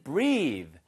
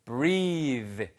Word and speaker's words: breathe: 얘는요, breath가 아니고요. B-R-E-A-T-H까지만 쓰면 숨이란 뜻입니다. breath, breath. 그렇죠? 예, 그런데 breathe 해서 E가 붙는 순간 소리가 바뀌는 거죠.